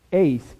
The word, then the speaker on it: eis: The word unto is eis, E-I-S, is the way to pronounce it.